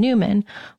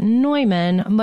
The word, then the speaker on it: Neumann: Newman. Most people outside of Philadelphia pronounce it Neumann, but in Philly, it's Newman.